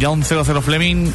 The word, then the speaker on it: John 00 Fleming: Y los artistas, que esto es importante, son John 00 Fleming y Christopher Lawrence.